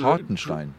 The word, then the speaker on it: Hartenstein: Dr- Hartenstein.